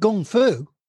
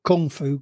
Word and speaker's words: gung fu: Because nobody ever heard of Gung Fu, which is the correct pronunciation, not Kung Fu. Gung Fu is the correct pronunciation. And if it's in Mandarin, it's Gong, G O N G.